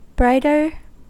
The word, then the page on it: brighter: American English